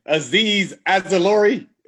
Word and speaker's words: Azeez Ojulari: Azeez Ojulari, is that right? You pronounced it perfectly.